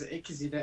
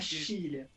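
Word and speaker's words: Xillia: é Xilia. Confere isso aí, Desmax. – Eu falava Gília. – Olha aí. É É, tem o som do Z, não é Xilia. É, tô...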